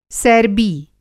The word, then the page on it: सैरबी: masculine noun